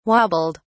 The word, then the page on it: wobbled: British English